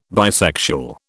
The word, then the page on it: bisexual: British English